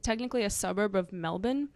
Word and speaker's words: Melbourne: Okay, folks, it's Melbourne, but she's talking about. She's saying Melbourne. – Melbourne. That's how the guy said to pronounce it. And I have heard it before, even though, yeah, it looks like you would say Melbourne, and which is how I've always said it in my head.